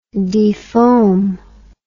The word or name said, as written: defoam